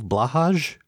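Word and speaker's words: Blåhaj: Do they qualify as some type of Blahaj? – Good question. – Which I recently learned is not pronounced that, but that's how I'm going to keep saying it.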